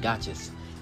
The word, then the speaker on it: gacha: It's gotchas. If y'all know what that is, it's called a gotcha. It's spelled G-A-C-H-A.